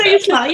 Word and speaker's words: Siuslaw: Oh yeah, the Siuslaw, yeah.